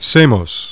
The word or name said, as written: SAMOS